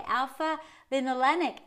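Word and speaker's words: alpha-linolenic: It is important for vegans to eat foods containing alpha linoleic. Oh, I'm sorry, I didn't say it right. Okay, alpha linolenic acid, a type of fat that can be converted into essential omega-3 fats, DHA and EPA in your body.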